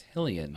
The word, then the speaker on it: Hylian: How do you say that? Shield? – Hell Island? – It's Hillion. – I always said Hillion. – Hillion? – Yeah, it's Hillion.